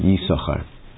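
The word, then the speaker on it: Yissachar: Right, as if you were just reading, you say Yisachar, because there are two sins. But we pronounce it Yisachar.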